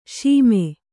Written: ಸುಮ

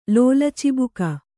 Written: ಲೋಲಚಿಬುಕ

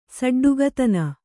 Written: ಷಡ್ಡಕತನ

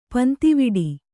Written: ಪಂತಿವಿಡಿ

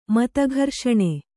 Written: ಮತಘರ್ಷಣೆ

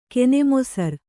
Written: ಕೆನೆಮೊಸರ್

